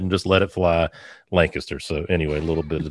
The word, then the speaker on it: Lancaster: So I'm impressed because a lot of people that, you know, they butcher that all the time. So you learn pretty quickly living around here. Lancaster is the way to pronounce it. So there is, it's pronounced the same way over by you? – It is.